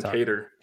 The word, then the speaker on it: Kattar: We were like Jeremy Stevens and Qatar. I forget the exact pronunciation. He took Calvin Kattar.